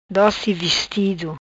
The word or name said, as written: Doci Vestido